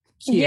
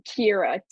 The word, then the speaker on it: Kiera: Kiera. Yeah, I have two H's. Kiera, two syllables. Kiera. Yeah, Kiera.